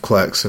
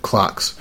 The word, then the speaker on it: Clerks: of Clerks and or as people who don't know how to read pronounce it, Clarks.